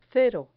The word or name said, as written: cero